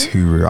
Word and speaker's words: Huru: It's Huru.